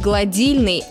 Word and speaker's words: гладильная: Но вот при этом доска для глажения будет гладильной, а не гладильной.